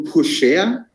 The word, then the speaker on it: Pourchaire: Weiterer Kandidat, Theo Pourchaire, spricht man den so aus, Kevin, weißt du das? – 100% richtig.